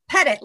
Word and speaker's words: Pettit: Pettit, like pet it.